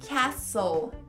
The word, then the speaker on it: castle: Castelo, castle. Castle, Natal, Christmas, Christmas. And Merry Christmas. Então esses são alguns exemplos onde o T é mudo.